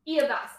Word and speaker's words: Iavas: Like, with the, I is pronounced E, right? Me. – Yes. – Okay. – Yes. – So, Iavas. I did say it right.